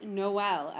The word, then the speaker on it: Noel: But it is pronounced Noel as well. Like the Christmas, like Noel, like.